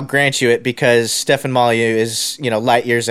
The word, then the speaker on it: Molyneux: It's called "Pretty Kitties Lick While Listening to Stefan Molyneux." Is that how you say his name? – Molyneux. Yeah, yeah. – Yeah, Molyneux.